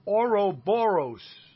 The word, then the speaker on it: Ouroboros: And then finally, Charm and Disaster with Ouroboros.